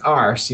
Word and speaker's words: arse: It's pronounced arse. You're supposed to pronounce the R.